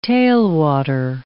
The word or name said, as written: tailwater